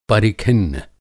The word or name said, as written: परिखिन्न